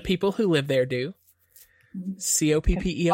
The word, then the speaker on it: Coppell: It's pronounced Coppell.